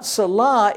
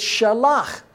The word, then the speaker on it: shalach: And the second part of Methuselah is not Salah, it's Shalach. Remember